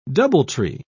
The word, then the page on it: doubletree: British English